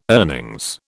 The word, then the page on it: earnings: British English